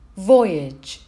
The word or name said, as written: voyage